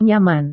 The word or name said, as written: nyaman